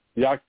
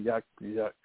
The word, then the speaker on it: Yasuke: So in the movie, they pronounced his name Yakse. And I was saying Yasuke, Yasuke.